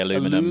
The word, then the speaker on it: aluminium: Is it just because you watch maybe too much American TV and they obviously say aluminum? – Aluminum.